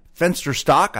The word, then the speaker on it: Fensterstock: Ellie and Bernice were also joined again by New York super lawyer David Grover from Grover and Fensterstock. I hope I pronounced that correct, David. – You did.